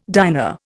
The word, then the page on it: diner: Indian